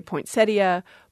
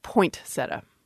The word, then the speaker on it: poinsettia: We can put our listeners at ease and say it's both, that it is standard to say poinsettia, poinsettia, and some speakers have put a T on that first syllable and you get poinsettia.